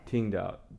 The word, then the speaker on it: Qingdao: Qingdao.